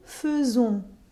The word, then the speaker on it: faisons: it's all too easy to forget that the pronunciation of it is exceptional. It starts out perfectly normal je fais, tu fais, il fait. But here's where it veers off from regular verbs nous faisons. And then back to, well, sort of normal with vous faites and ils font.